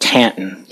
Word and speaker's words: Taunton: I grew up in a nearby industrial town called Taunton, Massachusetts, or as the locals pronounce it, Tanton.